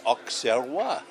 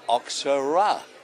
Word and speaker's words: Auxerrois: and spelled it phonetically, you know, that is Auxerrois or Auxerrois. – I saw a label. – It depends where you're from. – I saw a label called Gruner, G-R-O-O-N-E-R.